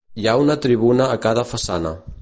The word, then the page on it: tribuna: Catalan